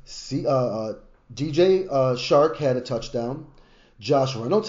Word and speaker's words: Chark: Was that how do you say it? Chark. Chark. Yep.